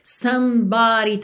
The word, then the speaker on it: somebody: Somebody também é importante perceber que esse é do meio não é pronunciado, não é pronunciado, desculpa.